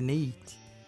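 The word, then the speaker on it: Neith: Tem a Nate, uhum né? Que é do Egito. Eu falei certo? Neite? Não, do deve Egito? ser Neite. – Neite.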